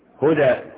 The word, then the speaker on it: هدى: قل ان هدى الله هو الهدى، هدى تكتب هدى ازاي؟ بالياء، لكن تنطق ايه؟ الفا. الدال مرسوم عليها ايه؟ هدى، هدى، دا، دا، مرسوم عليها ايه؟